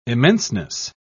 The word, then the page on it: immenseness: British English